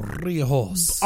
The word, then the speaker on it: Riojas: You got to roll the tongue. Riojas.